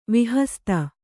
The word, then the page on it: ವಿಹಸ್ತ: vihasta